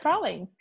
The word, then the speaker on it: praline: I know how to pronounce it, and it's pronounced praline.